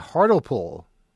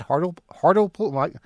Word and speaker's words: Hartlepool: Pronounce the E. Okay, didn't I just do that? Hartlepool? Yeah, you see, I'm not really sure how to read this because it didn't print very well as far as pronunciation guides. They don't convert well in ASCII. But I'm going to continue saying Hartlepool because that's how it's spelled, and I think that's really the way to pronounce it.